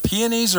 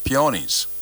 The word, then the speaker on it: peonies: Is it peonies or peonies? – You're right both ways. – I am. Okay. All right. – Peonies is kind of the modern version of say that peonies are what grandma planted.